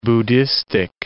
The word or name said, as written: Buddhistic